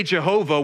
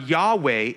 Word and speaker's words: Yahweh: So when we say Jehovah, we're butchering it big time. It ain't even close. It's rejected as any kind of, it's very wrong. It's more likely with the Y sound, Yahweh is closer, but it's still inaccurate because Hebrew doesn't have a W sounding letter or word.